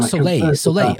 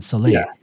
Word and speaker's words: Soleil: They worked on, I don't know how you pronounce it, Soleil, Ragnar Senti, Mega Drive game. – Soleil. Oh, really? – Yeah, it's like French, right? So for sun, Soleil. – Yeah, Soleil.